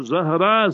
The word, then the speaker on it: Zahra: So you must say Zahra, Z-A-H-R-A-A. So then it will be like Fatima Zahra. So Zahra, Z-A-H-R-A-A. It means glittering, shining, and so forth. So that will be the meaning you must remember of Zahra. But today, what happens to our people, that they pronounce it wrong. And if you say Zahra, understood, that will mean that a flower. You see that? Zahra. So then the spelling will be Z-A-H-R-A-H. Zahra.